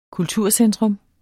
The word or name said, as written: kulturcentrum